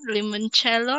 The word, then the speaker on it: limoncello: I think it's called limon limoncello or limoncello.